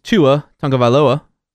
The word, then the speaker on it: Tua Tagovailoa: Number one is Tua Tagovailoa.